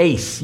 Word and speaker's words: eis: The Greek word, just for you guys that like that stuff, is the word eis. You would spell it in English, E-I-S.